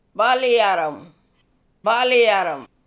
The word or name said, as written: Baliyarrem